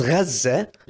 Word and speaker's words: Gaza: In Palestine, in this Gaza, I'm not even going to call it Gaza, is Gaza, the correct pronunciation.